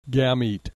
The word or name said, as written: gamete